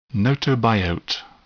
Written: gnotobiote

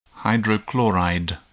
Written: hydrochloride

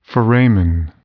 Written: foramen

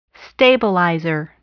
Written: stabilizer

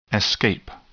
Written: escape

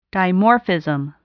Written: dimorphism

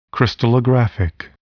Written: crystallographic

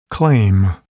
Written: claim

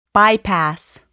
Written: bypass

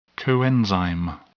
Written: coenzyme

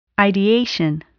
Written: ideation

